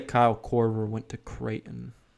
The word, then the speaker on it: Creighton: Is that pronounced Creighton? And U.S.? – Yeah, Creighton.